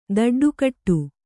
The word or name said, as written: ದಡ್ಡುಕಟ್ಟು